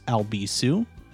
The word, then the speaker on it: Albisu: If you go over to Instagram, Facebook, or Twitter, you can just follow at Alex Albisu. The last name is spelled A-L-B-I-S-U.